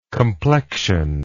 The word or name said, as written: complection